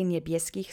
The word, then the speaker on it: niebieskich: Accent is on the middle syllable. Let's say niebieskich slowly three times.